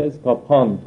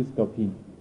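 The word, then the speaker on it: Scapin: اسکاپان البته تلفظ انگلیسی استاپینه، بعد از PIMSCAPIN در فرانسه اسکاپان تلفظ میشه، در انگلیسی اسکاپین.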